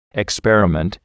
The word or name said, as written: experiment